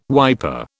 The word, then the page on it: wiper: British English